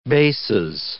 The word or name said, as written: bases